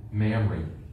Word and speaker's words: Mamre: Do you pronounce that like Mary? Is that how you pronounce it? – I always say Mamre. This has an extra B in it.